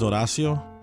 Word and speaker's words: Horacio: Oh, it's because my name, Horatio, in Spanish is Horacio. – Okay. – With a silent H.